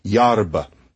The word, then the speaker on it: yerba: And then instead of a at the end, herba, erba, it's made into a kind of a schwa-y indistinct sound. So we have about. What is that? Is it an A, E, I, O, or U in terms of how it's said? Really, it's this muddy, crummy little sound. Lemon. What's that last vowel? Ugh, it's just that. Well, same thing. So, yarba. And so that's the word for grass in Romanian.